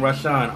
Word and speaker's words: Rahshon: Rashawn. R-A-H-S-H-O-N.